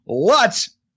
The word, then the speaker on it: Lutz: She said, and I love this quote, she said, tell Barry that the name of the town, Lutz, and she puts rhymes with Apollonia's butt, not her glutes.